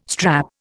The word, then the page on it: strap: Indian